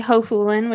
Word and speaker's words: Hohulin: So my name is Lindsay Hohulin, which my husband always says rhymes with no foolin' if you forget how to say it.